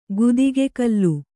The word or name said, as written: ಗುದಿಗೆಕಲ್ಲು